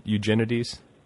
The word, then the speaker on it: Eugenides: That's another thing I like about, I'm always curious as to how, it's Eugenides. Is that how you pronounce it? That's how most people I know say it.